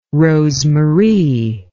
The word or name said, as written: rosemarie